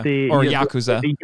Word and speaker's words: Yakuza: Yeah, Yakuza.